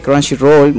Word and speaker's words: Crunchyroll: Vayan a verlo a Chunchirol. – Crunchyroll. Exacto, Crunchyroll.